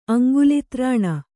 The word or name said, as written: ಅಂಗುಲಿತ್ರಾಣ